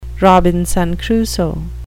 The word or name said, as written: Robinson Crusoe